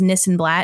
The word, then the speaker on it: Nissenblatt: My personal, you can find at Ari This and That, because my last name is Nissenblatt and it rhymes with this and that.